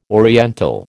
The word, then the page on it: oriental: American English